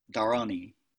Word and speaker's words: Dharani: And actually, it probably should be uh, pronounced Darani, like that, Darani.